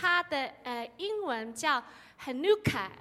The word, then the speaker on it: Hanukkah: 但是他们全部，你不要读 Hanukkah 哦，他们全部都是一个读音，就是 Hanukkah。